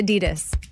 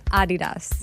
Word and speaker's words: Adidas: Yeah, well, that's the fun fact, is that Americans pronounce Adidas wrong. It's supposed to be Adidas, with a slight emphasis on the first syllable, not Adidas.